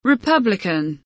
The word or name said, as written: republican